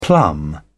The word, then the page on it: plumb: British English